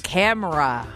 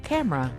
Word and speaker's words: camera: Now, you say it differently than I do. – Camera. – Camera. – You do two syllables. – You do two syllables. – Camera. – I like the old English pronunciation of three. Buy yourself a camera. – A camera. You're putting the wrong emphasis on the syllable. – However you say it, it's spelled the same. C-A-M-E-R-A.